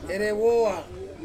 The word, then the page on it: rewoa: Ansus